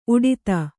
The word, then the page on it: ಉಡಿತ: uḍita